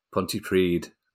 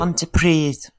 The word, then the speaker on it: Pontypridd: Pontypridd. Yeah. So I was close. So Pontypridd, you say, hang on, – I'll go, hold it. – Pontypridd.